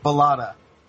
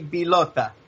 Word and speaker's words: Bilota: In English, that complicated last name is actually pronounced Blata. – Blata. – If it's Italian we're speaking, it would be Bilota.